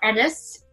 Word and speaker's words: Ennis: Ennis, E double N, like how the Brits say, okay. IS.